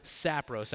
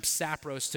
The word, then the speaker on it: sapros: Actually, the Greek word that's used here is a word, something along the lines of sapros. I'm probably pronouncing it wrong, but it's S-A-P-R-O-S, sapros, which literally translated means worthless.